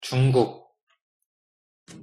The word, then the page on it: 중국: Korean